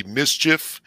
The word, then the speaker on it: MSCHF: It's a partnership with a company that is spelled MSCHF, but I guess it's supposed to be mischief or whatever.